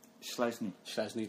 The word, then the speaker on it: Szczesny: They dropped Szczesny, didn't he? I can never say his name. – Szczesny, that's the one.